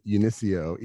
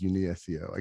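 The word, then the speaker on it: UniSEO: So actually, I tend to pronounce it Unisio, even though most people read it as Uni SEO.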